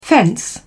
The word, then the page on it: fence: British English